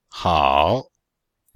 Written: hao3

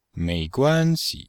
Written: mei2guan1xi5

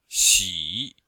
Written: xi3

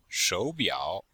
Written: shou3biao3

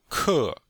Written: ke4

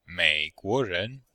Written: mei3guo2ren2

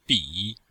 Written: bi3

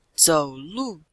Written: zou3lu4

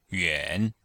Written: yuan3

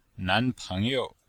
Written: nan2peng2you5

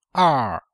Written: er4

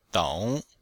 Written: dong3

